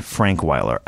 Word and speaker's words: Frankweiler: Frankweiler.